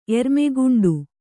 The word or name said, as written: ಎರ್ಮೆಗುಂಡು